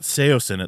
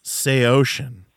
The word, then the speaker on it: Saosin: Like, no, it's not Saosin, it's Saosin. And, like, everyone had, like, these little things with, like, it was, like, this weird ownership. Like, no, you're completely wrong. That's not how it's pronounced.